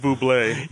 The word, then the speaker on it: Bublé: Michael Bubbly. – Yep. – It's pronounced Bublé.